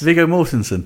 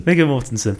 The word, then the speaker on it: Viggo Mortensen: Viggo Mortensen, uh, whose name I've probably just butchered, but no, I think that's almost right. yes, Viggo Mortensen. – Viggo Mortensen, perfect.